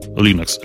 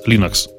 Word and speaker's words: Linux: and I pronounce Linux, Linux, он говорил. А, собственно, с тех пор мы всегда все говорили Linux.